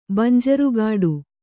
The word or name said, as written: ಬಂಜರುಗಾಡು